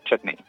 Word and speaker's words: chutney: Entonces me gusta pronunciarla de manera británica, chutney.